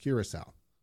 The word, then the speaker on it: Curaçao: I like how you know how to pronounce Curaçao because it's booze. – I might be actually be pronouncing it wrong. I'm just going with that. – No, no, I'm pretty sure that's exactly it.